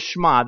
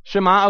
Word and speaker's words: Shema: And we were having a conversation about, I don't even recall exactly how we got onto the conversation, but it trended toward the Shema, the Shema, depending on who you're talking to. Did I say that? Did I pronounce that correctly, Dana? What would you say? Shema? Okay, all right, fair enough.